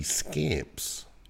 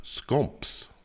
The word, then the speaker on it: Scamps: The other thing we learned is that one of her fellow teals, Sophie Scamps, it's not pronounced scamps. It's pronounced scomps, which sounds like the best ska punk band in the history of music.